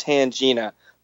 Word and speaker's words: Tangina: Her name in the movie is pronounced Tangina, but we are most definitely not going to be pronouncing it that way on this podcast.